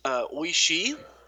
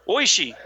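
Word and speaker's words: Oishii: Oishi. – Oishi. We're going to go with that. It's O-I-S-H-I-I.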